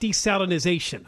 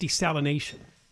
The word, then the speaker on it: desalination: And it's not desalinization. I hear people pronounce it that way. It's just desalination. There's no Z in there.